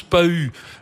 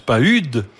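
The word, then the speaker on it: Pahud: Alors, on prononce Pahu euh, Pahu si on décide qu'il est suisse, on prononce Pahude si on décide qu'il est français.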